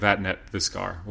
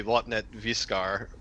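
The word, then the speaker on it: Vattnet Viskar: We always pronounce it like that, but it's like Swedish, so I think if you want to be real technical about it, it's probably Vatnet Viskar, but I'm not Swedish, and so I just didn't say it like that.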